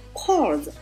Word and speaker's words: corpse: 其同根词呢有 corpse, C-O-R-P-S, 这里这个 corpse, 是那个 P 是不发音的，虽然它拼作 C-O-R-P-S。